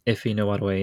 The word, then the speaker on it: Ife Nwadiwe: I think you did a good job there. That sounded right. Ife Nwadiwe, yeah.